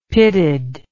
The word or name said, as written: pitted